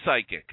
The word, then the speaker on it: psychic: And the word is psychic. – The P is silent.